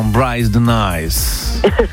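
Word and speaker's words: Brice de Nice: J'aime bien votre prononciation, Bryce de Nice. C'est ça, Bryce de Nice.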